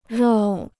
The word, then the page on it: 绕: rào